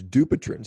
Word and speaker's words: Dupuytren: The correct pronunciation is Dupuytren.